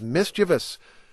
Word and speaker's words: mischievous: And many of you wrote in to remind me that the correct pronunciation is mischievous, not mischievous. Again, that's mischievous.